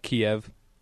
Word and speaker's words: Kiev: I always like words that have two vowels next to each other that you have to pronounce separately. – Like create and permeate. – Or like Kiev.